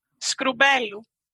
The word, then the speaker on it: Skroumpelou: Skroumpelou.